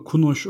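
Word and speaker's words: Kúnos: Ben şeyden okumuştum Macar halk bilimci Kunos Hatırlarsın onun bir Türk masalları derlemesi var. Orada da her Hocam masal... yine düzeltiyor olmuyorum ama Kunoş okunuyor olabilir. Kunoş mu? – Tamam.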